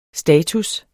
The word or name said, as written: status